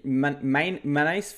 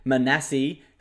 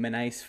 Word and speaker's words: Manase: So he told me all the commentators are saying his name wrong. His proper pronunciation is Manasi Fainu. Obviously, everyone's just calls him Manase Fainu, but it's Manasi Fainu.